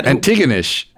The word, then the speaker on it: Antigonish: Antigonish.